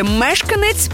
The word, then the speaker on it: мешканець: Наголос у слові мешканець падає на перший склад.